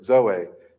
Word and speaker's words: zoe: And it's based on a different Greek word, zoe, Z-O-E, but the E is pronounced with the hard A there.